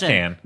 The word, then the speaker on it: Cannes: It's pronounced Can.